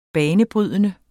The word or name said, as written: banebrydende